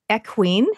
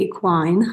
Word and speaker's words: equine: She's a highly experienced chiropractor specializing in both human and equine. Have I said it correctly? – I think we need to say equine.